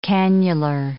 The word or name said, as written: cannular